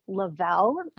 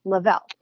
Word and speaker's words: Le-Vel: So the company name is actually Lavell, and it's spelled capital LE-VEL, Lavell, kind of like level up your body, level up your life.